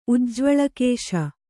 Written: ಉಜ್ಜ್ವಳಕೇಶ

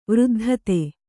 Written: ವೃದ್ಧತೆ

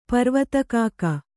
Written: ಪರ್ವತಕಾಕ

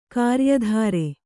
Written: ಕ್ರಯದಾರ

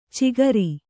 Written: ಚಿಕರಿ

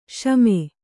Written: ಸುಮ

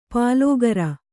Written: ಪಲುಗಿರಿ

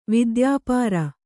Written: ವಿದ್ಯಾಪಾರ

